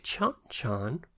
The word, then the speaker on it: Chon Chon: Now, Chon Chon, which has got a gorgeous name, C-H-O-N-C-H-O-N is how it's spelt, I presume. Mm-hmm. That's how you pronounce it.